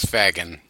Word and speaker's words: Fagin: And Philly, I would have known him if you said it right, which is Fagin.